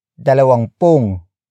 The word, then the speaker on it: dalawampung: You can say, dalawampung taong gulang. Dalawampung taong gulang. Dalawampung taong gulang. It means 20 years of age, or 20 years old. Word for word, it's dalawampung taong gulang.